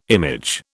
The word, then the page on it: image: British English